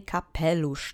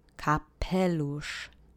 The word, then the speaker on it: kapelusz: You'll need your duck face again because of the sh phoneme. So again, remember, duck face, sh shoved through your duck face gives you sh. The accent is on the middle syllable this time. Let's say kapelusz together three times.